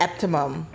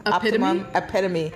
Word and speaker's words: epitome: This is the optimum. That's not the right way to pronounce that word. Optimum? – Epitome.